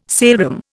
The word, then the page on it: saleroom: Indian